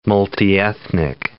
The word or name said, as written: multiethnic